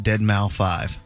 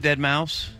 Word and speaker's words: Deadmau5: And if you're not up to date or trendy like me, you would have pronounced that Deadmau5. Because it's MAU5. Mm-hmm. – Is it Deadmau5? – Mm-hmm. – When you say it, you pluralize it? – Yes.